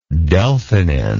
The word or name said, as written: delphinin